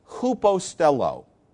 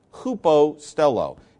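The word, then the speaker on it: hupostello (ὑποστέλλω): Paul uses a verb that is pronounced hupostello, hupostello. It begins with the vowel upsilon, which looks like a U when it's written out in English, hupostello.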